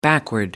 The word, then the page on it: backward: American English